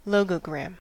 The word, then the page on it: logogram: American English